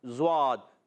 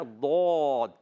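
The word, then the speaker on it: ضاد: نہ ڈواد پڑھنا ہے, نہ دواد پڑھنا ہے, نہ زواد پڑھنا ہے, اور نہ غاد. بعض بچے غین کے تلفظ سے پڑھتے ہیں غاد, یہ بھی غلط ہے بھائی. آپ نے پڑھنا ہے دود.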